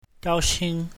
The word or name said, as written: gao1xing1